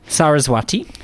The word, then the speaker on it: Saraswati: Saraswati.